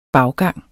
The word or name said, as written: baggang